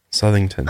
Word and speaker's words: Southington: So this house was located at 208 Meridian Avenue in Southington, Connecticut. And after she took a look at it, she was like, there's no way we can afford it. Where? – Southington, Connecticut. – Southington? – Yeah. Southington.